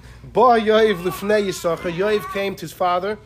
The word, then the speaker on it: יששכר: You know why Yisachar only has one, when you pronounce it, you only pronounce one shin, because one of them he gave away.